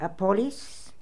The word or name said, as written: Appolis